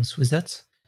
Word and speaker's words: Swizec: I tell everyone to call me Swiz because it's much easier for English speakers to pronounce than Swizec.